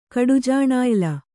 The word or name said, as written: ಕಡುಜಾಣಾಯ್ಲ